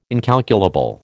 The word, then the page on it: incalculable: American English